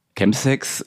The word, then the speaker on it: Chemsex: Chemsex, Linia, da hast du ganz recht, wird so ausgesprochen, kommt von Chems, Kurzform für Chemicals, also halt einfach den Gebrauch von Drogen beim Sex sozusagen.